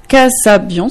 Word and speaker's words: Kasabian: Donc, pour cette fin d'interview, vous avez choisi une chanson, I Hear Voices de Kasabian, si je prononce bien.